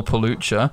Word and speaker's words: Pelucha: So if you were to Google Phil Pelucha, surname is spelled P-E-L-U-C-H-A, you will find me everywhere.